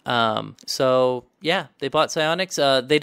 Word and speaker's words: Psyonix: Psyonix. Yeah, Psyonix.